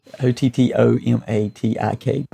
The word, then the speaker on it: Automattic: Yeah, and just to be clear, it's Automattik. But because what it is the way you pronounce it is Automattik.